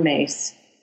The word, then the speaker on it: Myss: And I heard a speaker, a spiritual author, teacher named Carolyn Mace. It's spelled like Caroline Miss, M-Y-S-S.